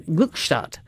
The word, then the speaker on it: Gluckstadt: They would say Gluckstadt. You know that? They wouldn't say Gluckstadt. Gluckstadt. That's how you say that, auf Deutsch.